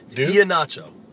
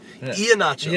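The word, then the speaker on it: Ihenacho: You've progressively pronounced that name worse as the season has gone. Ahinacho. You got the Duke part. Ahinacho. – Yeah. Ahinacho. – Yeah. You were saying it fine in like November.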